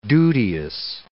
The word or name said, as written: duteous